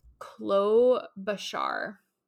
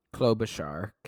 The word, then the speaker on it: Klobuchar: So you were curious about how to pronounce her name, and according to the pronunciation on Wikipedia, it is Klobuchar. – Klobuchar, okay. – Like, emphasis on the Klobuchar.